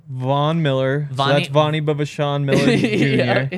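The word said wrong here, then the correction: B'Vsean: It's pronounced Bichon. – Bichon. Oh, you don't say the V? – Yep.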